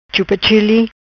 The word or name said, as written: Chupa Chili